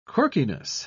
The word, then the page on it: quirkiness: British English